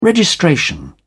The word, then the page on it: registration: British English